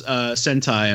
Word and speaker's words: Sentai: It's pronounced Hentai? – Yeah, no, it's pronounced Sentai.